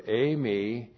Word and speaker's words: eimi: And then the to-be verb eimi, and it's a present tense, linear action sart. Linear action sart. So here we have the to-be verb eimi, E-I-M-I.